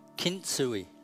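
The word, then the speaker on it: kintsugi: It's the Japanese art of kitsui. Did I say that right? Kitsui? What is it, darling? Kintsui. Kintsui.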